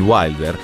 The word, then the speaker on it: Wilder: Sono anni difficili, ma il giovane Wilder, che nel frattempo ha americanizzato la pronuncia del suo cognome in Wilder, è fatto di una tempra fortissima.